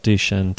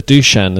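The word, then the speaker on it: Dušan: Yeah, and then we got the Dusan as well. So it's not even Dusan, it's Dusan Tadic.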